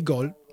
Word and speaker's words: gol: Donc nous on dit gol. Là c'est écrit G-O-A-L, mais parfois nous on peut l'écrire G-O-L tout simplement, okay, vu la prononciation, pour dire but.